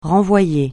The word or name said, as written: renvoyer